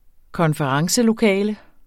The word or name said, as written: konferencelokale